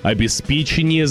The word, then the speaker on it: обеспечение: Вы берете трубку, выслушиваете претензии за испорченный счетчик, цель которого – обеспечение замеров потребляемой горячей воды. Или обеспечение. Словари дают нам два варианта, но чаще всего в приоритете с ударением на третий слог, то есть обеспечение.